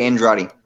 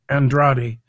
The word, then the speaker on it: Andrade: Do I say that right, or is Andrade? Tell me, my friend. – It's Jose Andrade. – I'm sorry. No Andrade. Problem. Say that again, that last name. – Andrade.